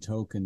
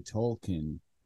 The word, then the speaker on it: Tolkien: And on like the latest season, they had an episode where Stan finds out that his name is actually Tolkien, like J.R.R. Tolkien, and they just say it like everybody else already knew the whole time.